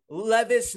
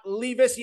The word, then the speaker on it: Levis: Will Levis, not Levis. Yes, I got the message, Kentucky fans. I said it wrong.